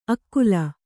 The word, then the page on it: ಅಕ್ಕುಲ: Kannada